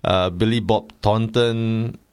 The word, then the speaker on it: Billy Bob Thornton: Billy Bob Thornton.